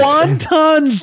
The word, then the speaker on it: wanton: Wanton damage. – Wanton damage. – You know what I'm talking about? – No, yeah. It's just wanton damage. – It's not pronounced like the Chinese food.